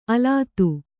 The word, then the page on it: ಅಲತೆ: Kannada